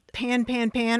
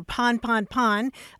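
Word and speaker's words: pan-pan: Yeah, I mean, everything you said is right about pan, pan, pan, or pon, pon, pon. I've heard it pronounced both ways, but you're right that it does come from a French word, pon, that means a breakdown or a mechanical failure.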